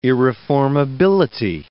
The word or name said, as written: irreformability